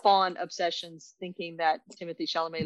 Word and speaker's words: Chalamet: You don't hear the T at the end. – Okay, so Chalamet.